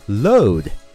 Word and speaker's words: load: 你听 load 是不是跟中文的搂一眼的搂发音特别的接近呀？